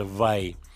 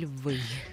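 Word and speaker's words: Vai: Фамилия произносится Вай. – Так. Что было удивительно, потому что мы всегда говорили «вы».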